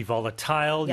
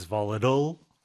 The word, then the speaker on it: volatile: So as an adjective, it could be volatile. You can pronounce it as volatile, right? More Americans would do that.